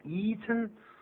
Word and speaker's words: eaten: Eaten，记住啊，eat 就是吃，但本来应该念成 eaten，可是 Have you eaten？这个时候我们说过用鼻音出来，这样的 eaten，我们要请 Karen 来念。